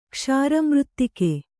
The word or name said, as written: ಕ್ಷಾರಮೃತ್ತಿಕೆ